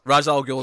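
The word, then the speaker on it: Ra's al Ghul: they don't say Raish properly, so you know, yes, swings and roundabouts. No, no, Raz Al Ghul is the correct pronunciation.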